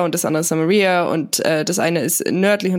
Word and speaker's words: Samaria: dass es wirklich Judäa und Samaria heißt.